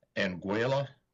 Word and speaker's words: Anguilla: Anguilla, is that how you pronounce it in the Caribbean? – Sounds right.